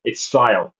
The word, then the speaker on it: Siadhal: And yeah, you did not pronounce that name right at all. It's Sile. So it's a strange jumble of letters, but it's pronounced like Kyle but with an S.